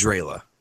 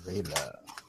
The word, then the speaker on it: Zreala: Her name is Zrayla. – Zrayla? – Yes. Would you like me to spell it for you? – Yes, please. – Z-R-E-A-L-A. – Say it again. – Z-R-E-A-L-A. Zrayla.